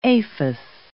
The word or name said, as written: aphis